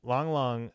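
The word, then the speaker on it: Lang Lang: But Long Long, I said it right that time, is, see, I'm learned.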